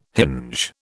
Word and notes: hinge: British English

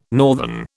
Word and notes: northern: British English

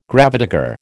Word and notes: gravedigger: American English

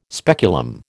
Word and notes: speculum: American English

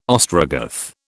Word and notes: Ostrogoth: British English

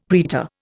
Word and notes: praetor: Indian

